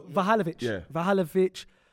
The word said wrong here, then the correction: Vlahovic: For me, Isak's cold. – You mean Vlahovic? – Yeah, Vahalovic, yeah. – Vlahovic, same thing.